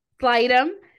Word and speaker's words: Slightham: Slightham.